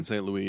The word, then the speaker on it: St. Louis: Is it Martin St. Louis, the hockey player? – St. Louis, yeah.